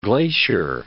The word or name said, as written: Glacier Bay